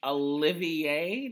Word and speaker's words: Olivier: I feel like people would know you for winning the Olivier, did I say it right, award? – Yes.